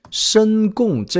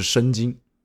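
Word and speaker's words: Şengün: 我记得有一个应该是火箭球迷吧，他在下面给我们留言啊，说申贡这个英文名虽然是这么发音啊，但好像在土耳其语还是什么语里面，它的发音就是申京。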